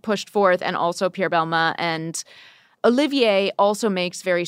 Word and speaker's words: Balmain: And we actually had a really great conversation with Olivier Rostang, who is the creative director of Balmain. Was that pronunciation good? – Perfect.